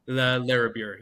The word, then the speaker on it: Larrabure: Is it Larrabure? Is that how you... Larrabure, yes. Larrabure.